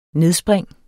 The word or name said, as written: nedspring